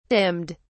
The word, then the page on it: dimmed: British English